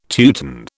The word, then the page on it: Teuton: British English